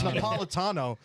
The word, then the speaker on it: Napolitano: Napolitano. That's what it was. It's Napolitano.